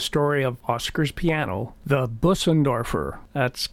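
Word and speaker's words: Bösendorfer: It's the actual German pronunciation is Bussendorfer. – Bussendorfer, oh, okay. – Yeah, all with right. A brr from the umlaut over the O.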